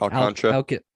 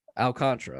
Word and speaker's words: Alcantara: How do I pronounce the last name? – Alcantara. – Alcantara. Okay, thank you.